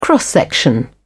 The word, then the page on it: cross-section: British English